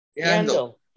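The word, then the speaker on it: Yandle: And also in hockey, this guy, Keith Yandel, is that how you say his name, Yandel? – Yandel. Yeah.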